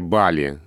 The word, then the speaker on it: Бали: Потому что если словарь Агейнга, который ты приводишь в пример, дает нам ударение Бали как единственное верное, то в словаре Штудинера, хотя у меня нет его под рукой, но я недавно смотрел эту статью, точно есть два ударения. И Бали, и Бали. Причем Бали стоит на первом месте, что подтверждает и приложение, которое я только что открыл. Там э, у Штудинера Бали как единственный вариант дается.